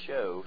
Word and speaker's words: shew: I recently heard about a church that said a fellow wasn't a Bible believer because he doesn't pronounce S-H-E-W as shoe. They pronounce it as show, so that proves they're not real Bible believers.